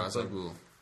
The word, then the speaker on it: Ra's al Ghul: His character, Ra's al Ghul. Am I saying that correctly? – Ra's al Ghul.